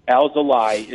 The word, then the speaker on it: Alzolay: It's now Alzolay. Alzolay is the correct pronunciation. We all were saying Alzolay. If Len Casper is saying it, it must be gospel. So it's Alzolay for now on.